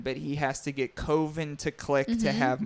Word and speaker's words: Coven: So Coven is spelled C-O-V-E-N, which we know as Coven. – Mm-hmm. – So Coven, but he pronounced it Coven.